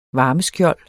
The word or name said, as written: varmeskjold